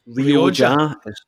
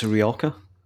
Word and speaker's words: Rioja: It's not pronounced Rioja. It's Rioja. So I just thought, I'll bring a little bit of culture to the show.